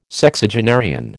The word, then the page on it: Sexagenarian: American English